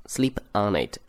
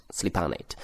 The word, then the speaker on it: sleep on it: sleep on it，注意它有连读，所以读作 on it。 sleep on it。